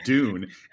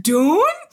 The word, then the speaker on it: Dune: By the way, because we are a worldwide cultural podcast, I would like to point out in America, it's called Dune and the French call it Dwin. – Yeah, but actually on this podcast, it's pronounced Dune.